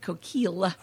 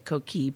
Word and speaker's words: Coquille: And actually, that's why I kind of bastardize the French pronunciation and make it coquille so that people know.